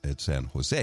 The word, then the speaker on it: San Jose: We don't pronounce the J, so it's San Jose.